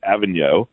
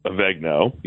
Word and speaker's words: Avenue: It's called Avenue, or locals might pronounce it Avegno. Either way, it's fine.